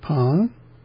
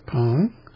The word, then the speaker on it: Peng: Yeah, the E is pronounced somewhat differently than we would pronounce it in the transliterated or in the phonetic form of the Chinese. It's P-E-N-G with an upward pitch change on the E, pronounced Pong.